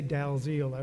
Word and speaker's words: Dalziel: He writes mysteries about uh, Pascoe and and uh, DL, uh, that's how you pronounce it. They say Dalziel out east.